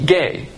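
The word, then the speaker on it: gay (γῆ): And those that are on earth, the word earth here is G-E, gay. It's not spelled G-A-Y. It doesn't refer to homosexuals. It's G-E. It's pronounced gay.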